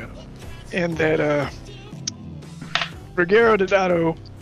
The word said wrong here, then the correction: Deodato: Ruggiero Deodato.